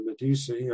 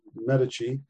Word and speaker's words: Medici: And in 1562, you had what was called the Edict of January by Catherine de Medici, or Medici, she's pronounced different ways, who was queen at that time.